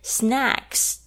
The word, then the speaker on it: snacks: Snacks 这边特别注意一下 snacks 的发音哦，你要表示点心的时候，那个 a 一定要往下，舌头要往下压。